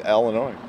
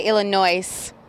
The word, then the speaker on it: Illinois: No, just when they say Illinois with an S. The S is silent.